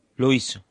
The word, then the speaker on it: Loyiso: Loyiso.